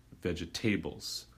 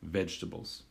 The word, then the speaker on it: vegetables: A gente tem que pôr mais ênfase na primeira parte da palavra vegetables. E não, a pronúncia não é vegetables. Tem palavra em inglês table, mas a palavra para vegetais é vegetables.